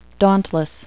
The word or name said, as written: dauntless